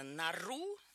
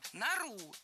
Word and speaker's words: нору: Давайте разбираться. «Нару» – это правильно, а вот «нору» все-таки говорить не стоит. Перед нами существительное, в котором ударение в единственном числе падает на окончание, а во множественном – на корень.